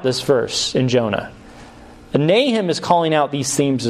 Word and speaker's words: Nahum: And actually, Nahum rhymes with this word compassion, rahum, which is interesting.